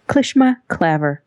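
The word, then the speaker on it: clishmaclaver: Klishmaklaver.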